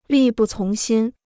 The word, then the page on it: 力不从心: lì bù cóng xīn